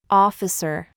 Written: lot

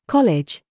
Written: COOK

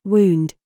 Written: GOOSE

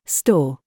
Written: THIS

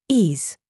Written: ZOOS

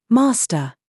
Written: SAUCE

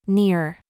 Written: near

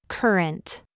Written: SCHWA

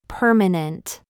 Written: mum

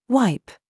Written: WE

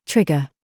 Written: GIG

